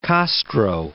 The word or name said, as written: Castro